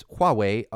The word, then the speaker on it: Huawei: It's Huawei.